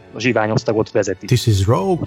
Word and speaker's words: Rogue: Még, még pár adással ezelőtt, talán kettővel, valahogy szóba jött a, a Zsivány 1-essel kapcsolatban, hogy néhányan a, az eredeti címét Rogue van helyett Rouge vannak ejtik. Még az én ismeretségi körömben is vannak pár, akik, akik ránéztek a címre, és kapásból gondolkodás nélkül úgy olvasták ki, hogy Rouge, pedig az Rogue.